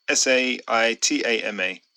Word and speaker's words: Saitama: So shout to the guys from, or gals from, Satama. Hope I pronounced that right. Do you know where that is? – Where, sorry? – Satama. I think it's Satama. Saitama. – Osatama. – No, Saitama.